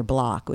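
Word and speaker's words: Bloch: And I got these lamps from this guy who works in wood, whose name is Peter Block, which is funny, but it's spelled C-H, not, but Block of Wood.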